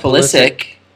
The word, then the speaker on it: Pulisic: Is surrounded by Mane, De Bruyne, Son, and Pulisic. So, I said it wrong. Pulisic. – No, it's Pulisic. Come on, man.